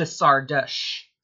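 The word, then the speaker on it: Szardos: I went with Sardosh, and he says, I got the consonants right, but the vowels were a little bit off. So for the record, Margali and Germain's last name should be pronounced a bit closer to Sardosh. It would like a schwa sound there at the end.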